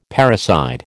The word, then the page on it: parricide: American English